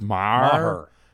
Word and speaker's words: Maher: Is it Maher? Just Maher. You got to get that H in the Maher.